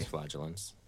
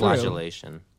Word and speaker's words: flagellants: Flagellants. – Aha! My speech pronunciation is coming self-flagellation. – Through. – Self-flagellation. The G is a J sound.